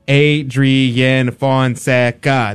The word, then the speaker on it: Adrian Fonseca: It's Adrian Fonseca. That's six syllables. That's way too many. – That's a lot. – That's way too many. I love my name, but gee whiz, that's a lot of syllables.